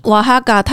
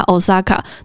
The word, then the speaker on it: Oaxaca: 所以瓦哈嘎它是一个什么样的地方？ – 瓦哈嘎它的拼音是 OAXACA，很多人都会直接念它 Osaka，但是它的正确发音其实是瓦哈嘎。